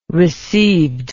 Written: received

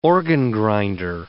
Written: organ-grinder

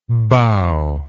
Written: bowingly

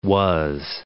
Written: was

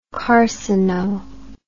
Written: carcino

